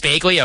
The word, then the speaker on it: Bagley: It's Bagley. Okay, let's go Bagley, because these guys should, seriously, they just need to all be called Smith.